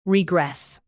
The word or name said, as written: regress